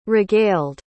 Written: regaled

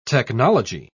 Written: technology

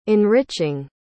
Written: enriching

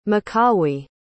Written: macawi

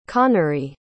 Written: connery

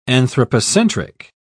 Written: anthropocentric

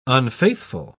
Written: unfaithful

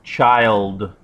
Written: child

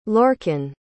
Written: lorcan

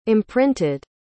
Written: imprinted

